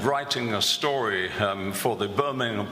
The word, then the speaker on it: Birmingham: I must remember to emphasize every syllable, because in Britain we say Birmingham just like that.